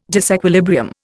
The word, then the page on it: disequilibrium: Indian